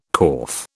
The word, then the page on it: corf: British English